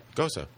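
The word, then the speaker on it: Gose: It's Gosa, right? – Gosa. Yeah. It's German. It's like Porsche.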